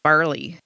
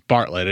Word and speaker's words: Bartlett: You know, I kind of noticed in the news clip, it kind of sounded like they were saying Barley. – It's Bartlett. It is Bartlett, but the way that they're pronouncing it, yeah.